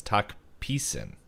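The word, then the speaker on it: Tok Pisin: Apparently, it's talk pissin, if I'm reading this correctly.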